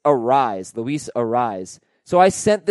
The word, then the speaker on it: Arraez: He pronounces it Arise, no S sound on the end like Luis Arias.